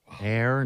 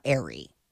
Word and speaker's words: Aire: Air instead of Wolf? – Mm-hmm. – I like Wolf. – And it's spelled A-I-R-E, but she's like, it's pronounced Air, not Airy.